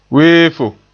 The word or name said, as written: weefo